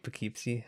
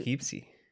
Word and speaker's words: Poughkeepsie: It reminds me of that scene from the Poughkeepsie tapes where the guy is just like hiding in the closet recording them the entire time.